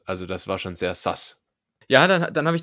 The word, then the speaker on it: sus: S-U-S, Sass.